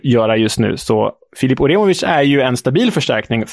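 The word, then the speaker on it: Uremović: Mm, de har plockat in kroatiska landslagsbacken Filip Oremov... bra uttal. Filip Oremovic.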